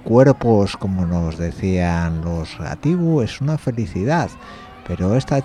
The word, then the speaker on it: Gatibu: Y en este de los Gabitu, como digo yo, Gatibu, sale uno que, no sé, podría parecer lastres mismamente, guapamente.